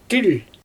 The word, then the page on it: tɪlʲ: Xyzyl